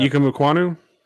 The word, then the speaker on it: Ikem Ekwonu: Ikem Ekwonu? – Yeah.